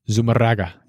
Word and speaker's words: Zumárraga: Bishop Zumárraga, is that how you pronounce that? – Zumárraga.